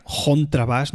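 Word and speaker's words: Chontrabass: Porque si bien ellos utilizan muchas veces la, la palabra alemana en sí, contrabas, bueno, con pronunciación suiza sería jontrabas, ¿no? Der jontrabas.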